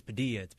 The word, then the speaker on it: Padilla: I was like, no, it's Padilla. You have to say it like that. And as I've grown older, I kind of just expect you. If you can say tortilla, you can say my last name.